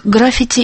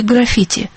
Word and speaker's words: граффити: Так граффити или граффити? Словарь допускает оба варианта. Интересно, что в России чаще говорят граффити, от итальянского граффиата. Нацарапанный, а в Литве граффити, что ближе к греческому графо пишу.